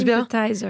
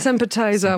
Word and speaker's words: Sympathizer: je le prononce Sympathizer. – Bien Sympathizer, voilà. On sent qu'il y a. – Comme l'apéro appetizer.